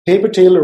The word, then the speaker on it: PaperTale: And the paper tail rhymes with paper trail, which basically is the traceability behind it.